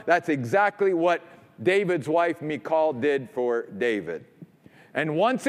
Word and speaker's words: Michal: And now God is going to use David's wife, who, by the way, is also the daughter of Saul, whose name is Michal. That's how you pronounce her name in Hebrew. It's Michal.